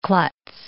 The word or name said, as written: klutz